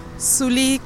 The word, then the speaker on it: Zully: Zully Caldera. – ¿Cómo se escribe tu nombre? – Z-U-L-L-Y.